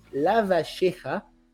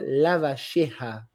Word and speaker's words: Lavalleja: esse nome é difícil de pronunciar, Lavacheja, é só letra L-A-V-A-L-L-E-J, então a pronúncia é, é Rio, Rio Platense seria Juan Antonio Lavacheja, mas, enfim, nome difícil.